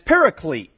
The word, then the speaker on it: paraclete: And myself included, until I saw the end of the word, I didn't know how to start it, the first syllable. And it's paraclete.